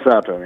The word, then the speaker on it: Sato: uh, is it, I can't pronounce the gentleman's name, I think it's Taka, Takuma Sada, Sada, is that his name? Takuma Sato, yeah.